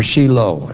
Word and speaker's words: Shiloh: Now in the King James Version, you'll see the word Shiloh, or Shiloh, as some would pronounce it.